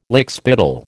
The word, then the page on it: lickspittle: American English